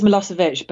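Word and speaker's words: Milošević: I think it's Milosevic, but you were pretty much spot on.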